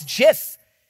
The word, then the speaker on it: GIF: And I instantly knew I'm wrong. I was like, I'm wrong. It's JIF. And there is a little bit of debate that it could, should, and is both, but it is technically a JIF. Just spell it with a J for crying out loud.